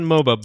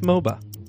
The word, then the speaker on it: Mhoba: The French word for rum and Mhoba both have a silent H in them.